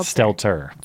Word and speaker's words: Stelter: Is it Stelter or Stelter? – Stelter. Stelter. – I say this incorrectly all the time. – I think you're confusing it with like seltzer.